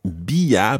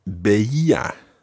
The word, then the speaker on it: veía: So it's not bia, but beia. – Beia. – Yeah, it's another one of those see how many vowel sounds you can fit into the one word. Beia. – Beia. – So it goes beia, beias, beia. – Beia, beias, beia. – Yeah, with the stress each time on the E.